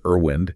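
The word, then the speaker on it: RWIND: Could be anything from, say, optimizing a connection in RStab, RStab, or maybe fine-tuning a wind simulation in R1HD, pronounced Irwind.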